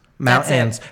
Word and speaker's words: mountains: Mountains. How do you say it? – Mountains. You don't pronounce the T. – Mountains. – Mountains. – Mountains. – Mountains. Nobody pronounces the T.